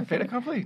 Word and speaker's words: fait accompli: I think last time I tried to say fait accompli and I told you I didn't know how to say it. I should have... – You said it just fine.